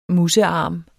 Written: musearm

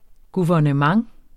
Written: guvernement

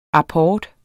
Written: apport

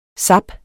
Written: zap

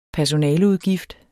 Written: personaleudgift

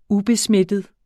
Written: ubesmittet

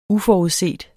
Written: uforudset

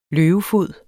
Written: løvefod

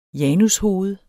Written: janushoved

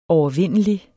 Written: overvindelig

